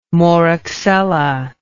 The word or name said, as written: Moraxella